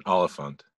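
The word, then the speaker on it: Olyphant: I think it's like Elephant, Oliphant.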